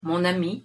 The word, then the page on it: mon ami: French